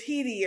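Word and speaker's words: Headey: The best part though, and I have to give it to Lena Hed- Hetty Hetty. Lena Head Headley it's Hedy or Hetty. I think it's Hedy is how they pronounce it.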